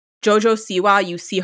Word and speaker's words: Siwa: That was Siwa.